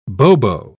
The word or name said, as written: bobo